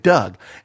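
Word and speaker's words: Dug: Even though apparently it's spelled D-U-G, I don't care. It's pronounced like a person named Doug.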